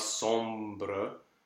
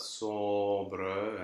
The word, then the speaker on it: sombre: Like, you really have to be careful to sing the vowel, so in this case the closed O, with not too much nasality, but you have to sing that vowel for the whole length of the note and put the next consonant really at the beginning of the next syllable. So not sombre, monde, pas sombre, and monde.